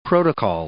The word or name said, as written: protocol